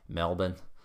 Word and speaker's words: Melbourne: Let's say a few years ago when I was flying down to Melbourne, Australia, and all the Aussies out here listening, if there are any, are probably laughing right now because it's Melbourne. That's not how they say it down there, Melbourne.